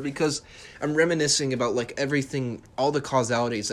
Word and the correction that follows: casualties: that causalities. I can't pronounce it right. Casualties. That's the right word.